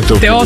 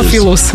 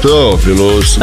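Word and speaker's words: Theophilus: Ja sam rekao, nije Teofilius. – Teofilus, malo ga skrati. Ti ga produžio. – Teofilus.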